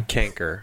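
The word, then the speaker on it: chancre: Was it a chancer? I don't know how to pronounce that word. You want to fill me in on that one? – Canker. – Canker. But why is it spelled CH? Especially when canker like canker sore is CANKER.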